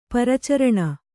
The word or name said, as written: ಪರಿಚಾರಿಣಿ